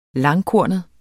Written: langkornet

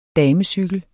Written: damecykel

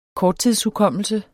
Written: korttidshukommelse

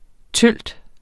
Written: tølt

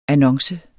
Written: annonce

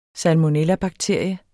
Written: salmonella

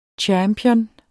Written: champion

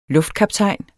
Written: LUFTKAPTAJNEN

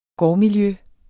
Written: gårdmiljø

